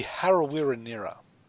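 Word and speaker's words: Harawira-Naera: As Dan mentioned earlier, Isaiah Yeo and Vili Kikau and Harawira-Nira. Is that Corey, how you say it? – Close. Corey Harawira-Nira. – That's pretty close.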